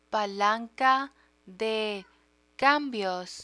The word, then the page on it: palanca de cambios: Spanish